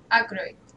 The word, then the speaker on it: Aykroyd: ¿Se, ¿Se, pronuncia así? Dan Aykroyd. – Aykroyd.